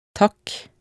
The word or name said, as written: takk